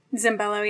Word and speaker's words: Zambello: And they bring on instead Francesca Zambello. Is that how you say it? – Zambello, yeah.